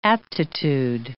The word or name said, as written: aptitude